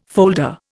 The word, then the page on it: folder: Indian